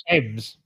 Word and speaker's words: Thames: That's the river that flows through London. It's pronounced Thames. – Thames.